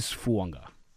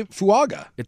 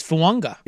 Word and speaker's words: Fuaga: And by the way, I know it is spelled Fuaga. There's a silent N just like Tua Tango Vailoa. It is Fuanga. – Oh, really? I would say Fuaga.